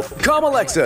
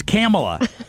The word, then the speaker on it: Kamala: Introducing Amazon Echo, Com Alexa Edition. This great new device will just give you a long, rambling answer to anything you ask. – He's mispronouncing that. It's Kamala. Kamalexa, not Com Alexa.